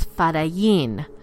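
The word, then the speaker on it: Fedayeen: and which is typically pronounced today as Fadayin.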